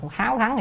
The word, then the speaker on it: háo thắng: Chữ này á, là hiếu, nhưng mà thường thường ở chỗ này không nên đọc là hiếu mà đọc háo thắng thì đúng hơn. Giống như háo sắc, chứ cho ai đọc hiếu sắc cả.